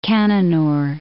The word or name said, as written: Cannanore Islands